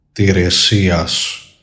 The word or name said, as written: TIRESIAS